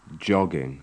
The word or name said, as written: jogging